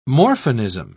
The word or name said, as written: morphinism